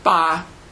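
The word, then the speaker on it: ba (八): Uh, because eight uh, is pronounced ba which sounds sort of like fa as in fatai.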